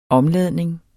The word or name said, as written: omladning